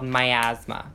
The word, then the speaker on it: miasma: It's called miasma and it's You're not what? – like my asthma like asthmatics. It's like it's okay. It's spelled M I S M A, miasma.